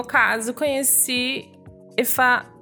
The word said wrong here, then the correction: Ifani: Puta. Ifani. Ifani. Conheci Ifani Gente, falei errado.